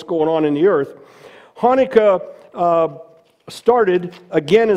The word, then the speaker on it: Hanukkah: Now, some say Hanukkah, but I want you to pronounce it with me. Hanukkah, Hanukkah. Start deep in your throat.